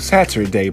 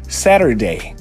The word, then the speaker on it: Saturday: No hum, inglês mais britânico, Saturday, pronunciando esse T. Mas, se você quiser pronunciar de uma maneira mais americana, esse T fica um D bem rápido para eles, para gente parecido com o nosso R de aranha, arara, setter, Saturday, Saturday.